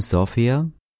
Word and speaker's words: София: The internationally accepted spelling of the word is S-O-F-I-A, but a spelling that reflects more closely the native pronunciation would be S-O-F-I-Y-A.